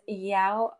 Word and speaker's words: Iao: I have so many places that I would love to name, but the first one that came to mind is Yau Valley State Park in Maui. Yeah, it's spelled I-A-O.